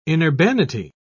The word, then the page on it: inurbanity: British English